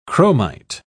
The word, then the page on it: chromite: British English